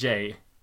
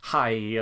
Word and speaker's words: Jai: barnet Jay alltså är döpt efter Jay Garrick, för han stavade J-A-I, och jag har alltid tänkt att då ska det väl uttalas hi eller något sånt där.